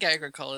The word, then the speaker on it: Agricola: I'm not even sure how to pronounce it. I think Agricola. – Yeah, nobody does, but I call it Agricola. – Yeah, I think Agricola is probably the acceptable pronunciation.